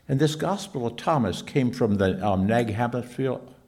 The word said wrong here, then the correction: Hammadi: or Hamish, how do you say it? Hammadi, thank you, there I got it.